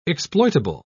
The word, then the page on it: exploitable: British English